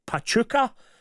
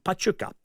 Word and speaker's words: Pachuca: We are fortunate to visit Mexico every summer, and we watch our team, which is Pachuca. – Pachuca. – Have I said that correctly? – I haven't a clue. – P-A-C-H-U-C-A. Pachuca. Pachuca. Pachuca? Might be.